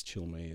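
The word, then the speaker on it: Chalamet: I think it's Chalmé is how you pronounce it. C-I-L. – Wait, how do you get C-I-L? – M-I.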